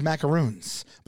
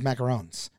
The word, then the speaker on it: macarons: And I always thought it was macaroons, but I found out last night it was macarons.